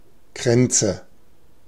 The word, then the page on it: Kränze: /ˈkʀɛntsə/